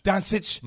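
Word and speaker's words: Dončić: Doncic and the Mavs.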